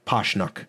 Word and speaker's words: Pasichnuk: His last name is spelled Pasichnuk, but apparently it's pronounced Pashnuk.